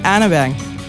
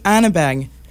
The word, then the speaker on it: Arneberg: Mais c'est pas prononcé comme ça en Norvège, n'est-ce pas? – Non. C'est comment? Ingrid Arneberg. – Il va falloir que tu me répètes ça sans fond, là.